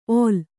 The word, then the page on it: ಒಲ್: ol